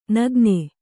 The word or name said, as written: ನೆಗೆಣ್ಣಿ